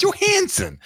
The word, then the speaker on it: Johansson: If you don't feel it in your nose, you're not saying it right. – Oh, all right. – It's Johansson.